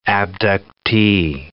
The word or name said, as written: abductee